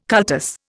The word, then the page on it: cultus: Indian